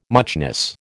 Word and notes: muchness: American English

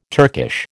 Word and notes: Turkish: American English

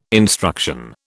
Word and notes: instruction: British English

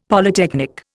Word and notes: Polytechnic: Indian

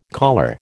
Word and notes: caller: American English

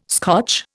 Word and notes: Scotch: Indian

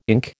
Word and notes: ink: American English

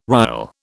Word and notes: rial: British English

